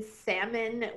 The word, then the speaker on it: salmon: I remember my freshman year is when I realized that the L in salmon was silent.